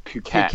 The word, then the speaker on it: Phuket: I believe it's pronounced Phuket. – Phuket, yeah, it is.